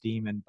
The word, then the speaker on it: daemon: It's spelled daemon, actually pronounced demon, but I like daemon better.